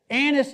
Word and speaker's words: anise: Anise, it's anise candy. A little pronunciation, shorten an A.